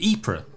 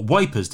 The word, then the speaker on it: Ypres: So in November 1914, they both quit the service and rented an abandoned cellar in the town of Ypres, or Wipers, depending on your pronunciation, and set up the Poste de Secours Anglais, or British First Aid Post, just 100 metres behind the trenches.